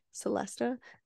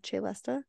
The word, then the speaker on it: celesta: I had to look up how to pronounce it because I thought it was like Celesta, but it said Celesta.